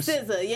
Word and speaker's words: SZA: And SZA, Swizza, how you say her name? – SZA, yeah.